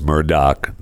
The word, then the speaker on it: Murdaugh: I apologize to the audience for remarking that OJ was saying Murdaugh and not Murdaugh, when apparently OJ was right, and it's Murdoch, not Murdaugh.